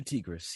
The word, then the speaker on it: Tigris: Like, I'm so used to pronouncing it like tiger, but Hugh Tigris, he's this very stoic sort of brother character he's a brother character who essentially speaks softly, carry a big stick around.